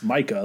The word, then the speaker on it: Micah: It's Micah, like everyone else pronounces that name.